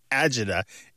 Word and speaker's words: agita: Usually these days it is spelled A-G-I-T-A, but you might see it as Argida, A-R-G-A-D-A, or Agida, A-G-I-D-A. And the reason there's some variation in pronunciation and spelling is that for a long time it was only transmitted orally. It comes to mainstream English through Italian Americans and from dialects of Italian, where there's this word that means acidity or heartburn.